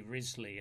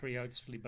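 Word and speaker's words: Wriothesley: Henry Risley, as it's pronounced, though it's not spelt anything like that. – I know, it's so confusing. It's It looks like Riotsley, but apparently it's pronounced Risley.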